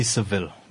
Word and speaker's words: Sevilla: or specifically at Sevilla, right? – The city's name is, in English they say Seville, but I just go always with the Spanish name, Sevilla.